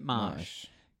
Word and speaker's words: mosh: So it was called MASH, but they pronounced it MOSH.